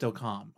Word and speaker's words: Delcambre: And then we're going to talk about, it's spelled Delcambre, Louisiana, but I think it's actually pronounced Delcam. I don't know. That's the way they pronounced it on the History Channel. So I'm going with Delcam because that's the way they said it on there.